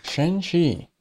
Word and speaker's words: Shanxi: Shanxi. – Yeah. – That sounded perfect, that pronunciation, by the way.